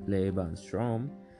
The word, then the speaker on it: Lebensraum: The German concept of Lebensraum, or the German pronunciation is Lebensraum, meaning living space, comprises policies and practices of settler colonialism, which proliferated Germany from the 1890s to the 1940s, first popularized around 1901.